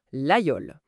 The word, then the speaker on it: Laguiole: Pour quiconque s'intéresse à la région, Laguiole, ça se prononce impérativement Laguiole.